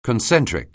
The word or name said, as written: concentric